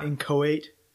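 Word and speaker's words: inchoate: Incohate. – Yeah. – It's spelled like inchocate. – Yeah. – But incohate. It's like that within ourselves, we find the seed of immortality placed there by baptism.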